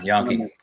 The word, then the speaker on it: Yonke: That's how you say your last name, right? – Okay. Yeah, Yonke.